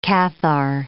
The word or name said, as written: Cathar